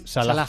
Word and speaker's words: Salah: Oye, lo correcto es Salah.